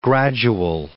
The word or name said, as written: gradual